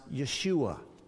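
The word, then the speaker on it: Yeshua (ישוע): The Hebrew is usually pronounced Yeshua.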